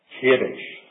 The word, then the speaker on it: Kadesh: Journey, that says, set forward from Kadesh, as we pronounce it in English.